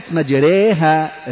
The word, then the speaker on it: مجريها: فكلمة مجريها ري هي أن تنحو بالألف نحو الياء، را الراء المفتوحة هكذا، والراء المكسورة ري، إذا أردت أن تنطق راء ممالة فتقول ري مجريها.